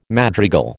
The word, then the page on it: madrigal: American English